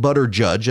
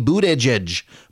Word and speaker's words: Buttigieg: it's actually Pete Buttigieg, not Pete Boot Edge Edge or Pete Butter Judge.